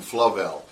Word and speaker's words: Flavel: He spells his name with the same syllables as navel, but we call him Flavel.